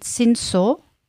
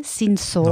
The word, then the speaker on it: Cinsault: Und zwar haben wir, und ich bin mal gespannt, ob du irgendwas davon schon kennst oder schon mal gehört hast, 60% Cinsault. Also schreibt man C-I-N-S-A-U-L-T, Cinsault.